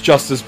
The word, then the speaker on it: Justus: It's spelled J-U-S-T-U-S, and it's pronounced Justice, but the wording is that it's just us, meaning that it's just the four Monkees.